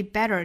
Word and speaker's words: better: 那我们注意 better 这个单词呢，里面的这个 t 是要半浊了，读成一个 flap t，舌尖呢是在这个天花板的位置轻轻的一弹而过，不是在上齿龈的位置，是往后走啊。